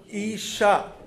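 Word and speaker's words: Isha (אישה): The Hebrew word for woman is Isha.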